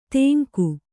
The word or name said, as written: ತೆಂಗು